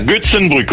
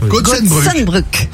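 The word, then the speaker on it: Goetzenbruck: Là, on est en Moselle avec vous à Gotzenbruck. – À Gutzenbruck, oui, c'est ça. – Ça va? – Non, tu prononces pas bien, il t'a repris. – C'est pas mal. – C'était bien. – Gutzenbruck.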